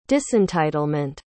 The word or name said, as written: disentitlement